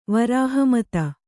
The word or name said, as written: ವರಾಹಮತ